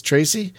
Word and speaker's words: Tracy: I keep saying Trees. Mm-hmm. It's Tracy. Like T.